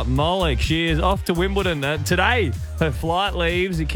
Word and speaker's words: Wimbledon: Wimbledon. Wimbledon.